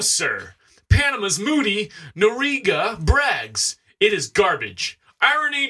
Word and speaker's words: Noriega: This is spelled N-O-R-I-E-G-A. – Noriega. – Yeah, Noriega.